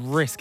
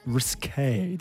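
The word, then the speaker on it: risqué: Not risk, it's risque. Do you understand? – Oh, but is it pronounced like risk, or it's meant you're meant to pronounce it as risque? – I think it's pronounced as risque.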